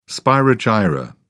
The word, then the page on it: spirogyra: British English